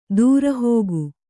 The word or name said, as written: ದುರುಹಿಕೆ